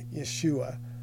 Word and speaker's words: Yeshua: So the Greek name would be pronounced something akin to Jesus, not the Hebrew name, which traditionally is, is, is pronounced Yeshua.